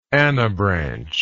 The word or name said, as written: anabranch